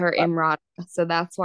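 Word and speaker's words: Emrata: then I've got another pop culture that Pete Davidson apparently is dating Imrata. – Yeah, that's how you say her name.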